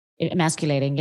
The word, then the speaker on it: emasculating: And it was like the worst thing that you could ever be as a man, because it was completely emasculinating. Is that right? Emasculinating? I don't know if I'm saying that right. – Emasculating, yeah. – Emasculating.